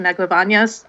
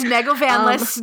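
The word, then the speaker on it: Negovanlis: Would love to meet Elise Bauman and Natasha Neglavanyas. I did not pronounce that correctly. – It's Neglavanyas, don't worry.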